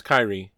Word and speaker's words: Kyrie: Is pronounced Kyrie.